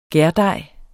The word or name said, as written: gærdej